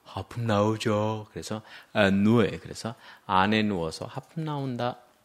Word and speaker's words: ennui: ennui, ennui. – 지루함, 권태. 발음이 ennui, 그러니까 어때요? 네, 아무리 봐도 발음이 이상하죠? 이거는 왜냐하면 프랑스에서 와서 그래요.